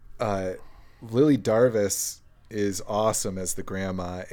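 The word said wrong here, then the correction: Darvas: She's Hungarian. I have it on good authority. It's pronounced Darvash. – Oh, okay. Darvash.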